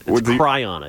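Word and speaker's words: chyron: Is it the Chiron or the thing on the screen? – It's Cryon. – Cryon. I always mispronounce it.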